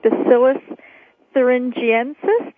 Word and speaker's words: Bacillus thuringiensis: And you started telling us about BT toxin, Bacillus thuringiensis. Did I pronounce that correctly? – Perfect, perfect.